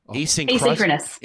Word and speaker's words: asynchronous: How do I say that word? There's no centri- Asynchronous. Asynchronous. Asynchronous. Jeez. Asynchronous.